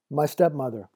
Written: my stepmother

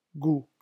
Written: goût

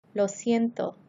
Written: lo siento

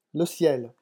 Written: le ciel

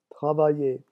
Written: travailler

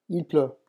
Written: il pleut

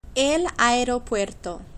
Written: el aeropuerto

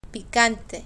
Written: picante

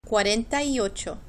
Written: cuarenta y ocho

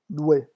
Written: louer

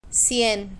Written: cien

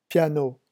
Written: piano

